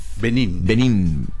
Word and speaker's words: Benín: Benín. – B grande, ¿no? Decíamos también en la primaria. Benín. Acento en la I.